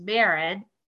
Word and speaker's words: Mairin: but it's like Aaron with an M, so it's Mairin. – Oh. – And the last name is Da Maren, but phonetically it's read Da Maren, so it'd be Mairin Da Maren.